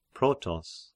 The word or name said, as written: Protos